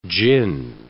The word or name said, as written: jinn